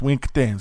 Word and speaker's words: winkte: But that conversation, two-spirited people, what they call them, winkte. That's how you say it in my language. Winkte is two-spirited.